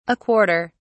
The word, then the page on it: a quarter: British English